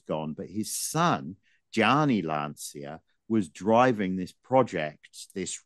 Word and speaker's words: Lancia: Well, Lancia are in the house, aren't they? This was the year of Lancia, or Lancia is the correct pronunciation, isn't it? Well, I was struggling with the Italian pronunciation before.